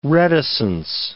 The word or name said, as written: reticence